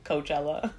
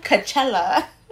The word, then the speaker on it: Coachella: Yeah, it's like his own version of his Coachella. Mm-hmm. So it's like, I'm pretty sure like this might affect. – First of all, I just love the way you just said Coachella. It's Coachella.